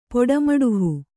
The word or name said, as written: ಪಟ್ಟಮುಡುಹು